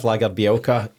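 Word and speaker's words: Lagerbielke: Gustav Lagerbielka, he's linked with Serie A.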